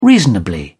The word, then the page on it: reasonably: British English